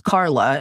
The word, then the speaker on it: Carla: I'm all about the C sounds because my name is Carla. And so therefore, clearly everything in my life has to start with a hard C sound.